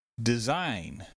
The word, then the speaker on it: design: Design. Design uses a short E, a Z sound, and a long I. Design.